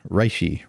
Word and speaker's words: reishi: Next up, reishi. How do I say it right? – Reishi mushroom. – Reishi. – Australia is how we say it.